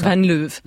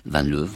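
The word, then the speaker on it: Van Leeuw: On va écouter le procureur fédéral de Belgique, Frédéric Van Leuve, je sais pas si ça se prononce comme ça. – Van Leuve. – Van Leuve, voilà.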